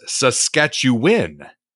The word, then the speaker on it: Saskatchewan: The proper way to pronounce, as I said it, Saskatchewan, is Saskatchewan. Saskatchewan. Am I saying it right? Saskatchewan. That's what he says here. But if you're from here, usually it just gets shortened to Saskatchewan.